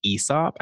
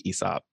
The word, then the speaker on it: Aesop: You actually pronounce it Aesop. And I only learned that because a friend worked there, but no one says Aesop.